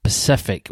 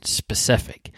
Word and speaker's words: specific: Love listening to this podcast and to chuckle every time at Pacific wording, haha, yeah, because I say Pacific rather than specific. And I kind of spell it that way as well.